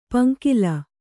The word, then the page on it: ಪೊಂಗಲ್: Kannada